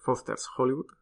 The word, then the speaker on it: Foster's Hollywood: Y ahí sí que, a ver, tampoco había muchos locales ni había muchas opciones, pero había un Foster Hollywood, que es donde Foster's Hollywood, Siempre pongo la S mal, no sé por qué. – Que no, que no, que lo has dicho bien.